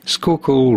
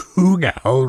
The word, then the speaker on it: Schuylkill: And that name, Schuylkill, the way it's spelled S-C-H-U-Y, kind of shkui, and then kill.